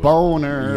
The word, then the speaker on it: Boehner: Boehner. – Yeah, exactly. Because Boehner, I don't see any of those letters in there. – It's like B-O-E-H-N-E-R.